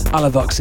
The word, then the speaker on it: Alavux: This is uh, brand new from Avalox, Alavux even. Man, pronunciation some of these names I should know.